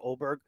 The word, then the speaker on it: Åberg: And I mean, just to reiterate, I heard Åberg. I heard the A isn't pronounced like an A in Swedish. It's an O. So I heard people saying Åberg.